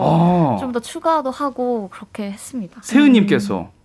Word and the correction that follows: 시은: 시은 시은님, 네. 예, 세은이 제가 발음이세요.